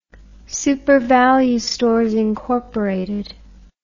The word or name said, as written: supervalu stores inc